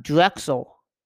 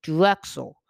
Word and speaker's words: Drexel: But Drexel, I said it right this time, guys. Not Drexel, Drexel.